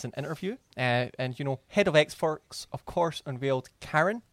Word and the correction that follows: Carrion: No, that's not how you pronounce Carrion. – That. Like Carrion. – Yeah. Carrion, as in dead meat, basically.